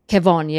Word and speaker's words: Kevon: Kevon, yeah, Kevon. – Okay. – Yep. It's like Kevin with an O. He probably gets this all the time, but yeah, it's Kevon.